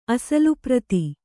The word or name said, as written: ಅಸಲುಪ್ರತಿ